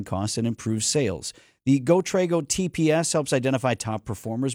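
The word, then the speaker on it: GoTrego: Trago is spelled T-R-E-G-O, so it's GoTrago.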